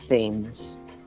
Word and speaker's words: Thames: And so the seagull pronounces the River Thames Thames. And it's a good thing, because he ends up rhyming it with flames.